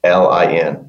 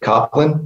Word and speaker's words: Coplin: So you can reach me on the web at RICK COPLIN. Looks like Rick Coplin, but it's pronounced Rick Coplin.